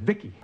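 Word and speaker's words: V.I.C.I.: V-I-C-I, I pronounce it Vicki.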